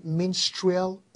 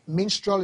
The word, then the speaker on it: Minstrel: And if you go on YouTube, if you want to search it on YouTube, just type the name Minstrel Osas. Minstrel is spelled M-I-N-S-T-R-E-L Osas.